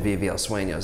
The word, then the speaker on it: Vive el Sueño: Or if they need more education, Vive El Sueño is there.